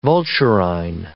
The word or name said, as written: vulturine